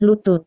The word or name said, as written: lutut